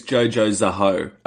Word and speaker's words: Jojo Zaho: All right, the next one we have is Jojo Zaho. Am I saying that right? – No, I think it's Jojo Zaho. – Oh. – Like, she's a ho.